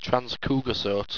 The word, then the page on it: transcugousote: owstwecian